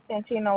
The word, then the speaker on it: Santina: Santina Walker. – All right, spell that for me. – S-A-N-T-I-N-A.